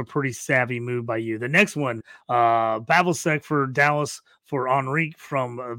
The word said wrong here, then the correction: Pavelski: Yeah, I will fix you on the pronunciations here, always. But hey, no problem, buddy. We got Pavelski for Dallas. – Pavelski, thank you.